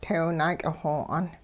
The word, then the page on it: teew-naak'ixo'aan: Hupa